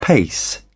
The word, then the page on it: pace: British English